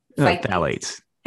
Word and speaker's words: phthalates: Phthalates.